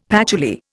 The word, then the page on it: patchouli: Indian